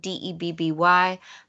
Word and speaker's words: Debby: My name is spelled Debby.